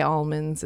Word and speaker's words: almonds: They pronounce almonds like almonds. Like, they got almonds farms. You can't say the L. You say almonds. Mm. It's really not so good. Don't be like that, people. Say almonds.